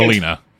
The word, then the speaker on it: Melina: All right, we finally get the mouth thing from Milana. From Milena. – For a second. – Melina. – Melina. I said it wrong every time.